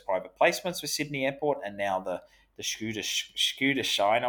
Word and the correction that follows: Schuldschein: I'm probably getting worse on my pronunciation there. So interesting to see. – The Schuldschein. – Thanks, Marika. That's what I was trying to say.